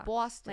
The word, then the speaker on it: Boston: It's like Boston because it's like aw. It's like aw. – Like aw? – Aw. – Boston. – Like boss. Yeah. – Boston. – Not ba. It's like ba.